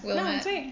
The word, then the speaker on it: Willamette: I like Napa Cabs and I like Willamette Valley. Am I saying it wrong? – Willamette.